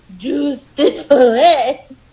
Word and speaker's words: jus de poulet: Juice de poule. Wait. Juice. Juice. de poulet. Jus de poulet.